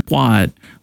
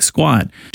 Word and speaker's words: SQ4D: The demo house was built by construction firm Quad, which is spelled Sierra Quebec number 4 Delta. So SQ4D, when you print it out, it looks like squad.